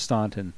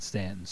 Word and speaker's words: Staunton: And Stanton is spelled S-T-A-U-N. It's properly pronounced Staunton, but all these country folk around here call it Stanton.